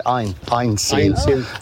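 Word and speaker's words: Għajnsielem: It's pronounced, it's GH, but they pronounce it Ein Seelen.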